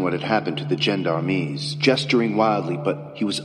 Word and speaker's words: gendarmes: Gendarmes.